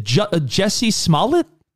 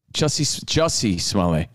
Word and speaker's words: Jussie Smollett: Is it Jesse Smollett? – Jussie Smollett.